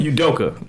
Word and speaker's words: Udoka: Udoka. – Udoka.